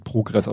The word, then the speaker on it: Progress: Gut, dann sagt er nochmal, dass das äh, Leck an der ISS ist eben ein Pro Progress, Progress, ich glaube, es wird Progress ausgesprochen,